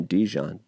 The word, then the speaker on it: Dijon: I believe you pronounce his name Dijon, D-I-J-O-N, his song Many Times, which is Straight Cash Money.